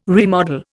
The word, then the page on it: remodel: Indian